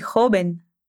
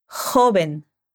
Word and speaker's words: joven: Parece muy joven quero que você preste atenção na pronúncia da palavra jovem em espanhol. O J é um som que vem da garganta. Repete. Joven. O V tem som de B.